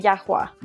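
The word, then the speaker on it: llajwa: Am I saying that right? Is it yajua? – No, it's actually Yahwa.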